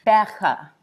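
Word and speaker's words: Gqeberha: Okay, I can't do a click. But I have watched the videos over and over, because even the BBC abroad had a video on how to say it. It's something like Berger.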